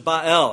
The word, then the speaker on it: Baal: Ahab, however, looked to his pagan neighbor to the north, Ethbaal, the Phoenician king of the Sidonians who worshipped Baal. That's how you say it in Hebrew.